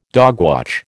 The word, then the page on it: dogwatch: American English